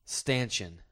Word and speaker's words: stanchion: The word for an upright bar, post, or frame forming a support or barrier is pronounced stanchion.